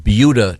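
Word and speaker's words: Buda: we have yet to get to sports, but we were discussing a very interesting news item from Beulah, Texas.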